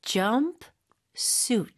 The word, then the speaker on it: jumpsuit: 근데 발음 주의하셔야 돼요. jump suit. So 총 이음절이에요.